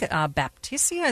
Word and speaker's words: Baptisia: Coming up after the top of the hour news, we're going to be talking about the plant of the week, Baptisia.